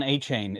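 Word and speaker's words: Achane: Devon A-Chain is what A-Chain.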